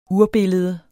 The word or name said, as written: URBILLEDERNES